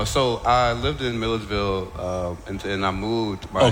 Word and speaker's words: Milledgeville: How far is that from the town I can't pronounce, Midgerville or whatever? – Milledgeville. – So Milledgeville.